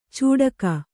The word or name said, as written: ಚೂಡಕ